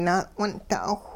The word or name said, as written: na'wun'da'awh